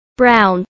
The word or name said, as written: brown